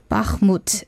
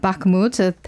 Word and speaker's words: Bakhmut: Bakhmut is how you pronounce it, because I think I said Bakhmut.